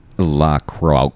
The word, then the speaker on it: La Croix: It's pronounced La Croix.